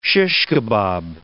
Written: shish kebab

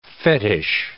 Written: fetish

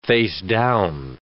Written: facedown

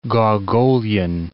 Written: Gogolian

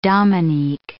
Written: Dominique

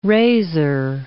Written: razor

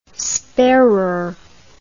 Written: sparer

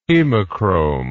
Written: hemochrome

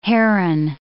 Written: Herren

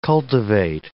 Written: cultivate

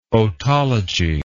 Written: otology

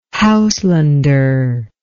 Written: Houselander

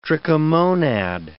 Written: trichomonad

